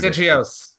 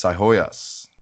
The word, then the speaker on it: Sihoyos: Sigios. – It. That's wrong. It's Sihoyos. – I would not Sihoyos. Have guessed. I would not have guessed the silent G.